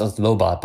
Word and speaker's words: Lobot: I always pronounce it French as if the T is silent. But Lobo. Is it Lobot? – It's Lobot, but I like the French pronunciation, Lobo.